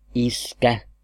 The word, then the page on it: dad: /dæd/; [dæd]